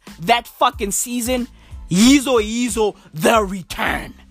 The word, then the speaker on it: Yizo Yizo: You want me to spell it? Y-I-Z-O. Y-I-Z-O. Okay? Yeezo Yeezo. Bro. Yeezo Yeezo had season one.